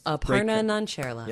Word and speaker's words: Aparna Nancherla: Aparna Nancherla.